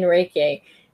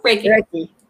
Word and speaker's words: Reiki: And like you said, what after you have your book and everything else, you're going through yoga teaching right now and Reiki. Mm-hmm. Did I say that? I didn't say that right. – Reiki. Yep, Reiki.